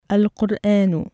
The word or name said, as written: اَلْقُرْآنُ